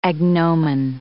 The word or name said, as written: agnomen